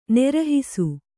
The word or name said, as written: ನಿರ್ಹಾಸ